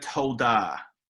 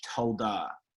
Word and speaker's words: towdah: Yeah, well, one of the words is the word toldah, which is spelled T-O-W-D-A-H, toldah.